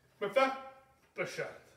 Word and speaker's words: Mephibosheth: And his name was, I wrote it and I pronounced it, Mephibosheth. I was practicing. Okay? Mephibosheth.